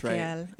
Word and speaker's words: Michael: It's not like Michelle or... – Oh, no, it's Michael. – Right. – Okay. – My full name is actually Alec Michael Powell,